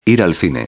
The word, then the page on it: ir al cine: espanhol